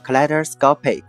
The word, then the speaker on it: kaleidoscopic: OK，拼成 K A L E。 I D O S C O P I C，OK，再来一遍，K A L E I D O S C O P I C，这个词啊就是形容词，叫做千变万化的。对，接下来啊，我们来看一个和这个 kaleidoscopic 相关的例句：A rainbow kaleidoscopic energy band is around the object。